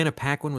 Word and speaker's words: Paquin: I loved Anna Paquin in it. I thought she was, am I saying her name right? – Yep. – Okay. Yeah. Anna Paquin was fantastic.